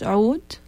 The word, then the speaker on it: oud: My instrument is oud. It's difficult to pronounce it in English, oud, with the ain. But we spell it like O-U-D.